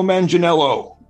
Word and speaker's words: Manganiello: All this movie needs is Joe Manganiello.